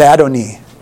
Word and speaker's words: Adonai: Y-E-H-W. Yeah, whatever. It's Jehovah, right? And or pronounced Yahweh. And the second one is Adonai. A-D-O-N-E-E. Okay? – A-I. – Yeah. Well, it's a variation of it. It's a variation of it. And some translations, I believe, say Adonai, some say Adonai.